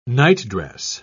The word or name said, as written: nightdress